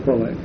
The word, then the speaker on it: Kwolek: Is that how you pronounce it? Kwolek? – Kwolek, yeah. – Kwolek. K-W-O-L-E-K. – Right. – This is for the typist so they can transcribe it properly.